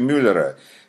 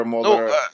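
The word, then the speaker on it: Mueller: Если Трамп закроет комиссию Мюллера, тут, кстати, я, ну, мне просто вот не могу не сказать, но не произносится он Мюллер по-русски. Мюллер — это 17 мгновений весны. Он произносится как Моллер по-английски.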